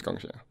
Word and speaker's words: Guangzhou: Shanghai, I'm going to struggle to pronounce this one, Guangzhou. – I'm pretty sure it's Guangzhou.